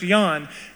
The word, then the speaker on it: Jan: And, you know, again, this is not new to me, it's normal, so it's spelled J-A-N, but it's pronounced Yawn, and she looks at the form, and she says, honey, you're in America now, and it is Jan.